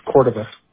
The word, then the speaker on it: Cordova: It's Cordova. – Could you spell that? – C-O-R-D-O-V-A.